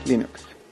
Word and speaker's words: Linux: Hello, this is Linus Torvalds, and I pronounce Linux as Linux.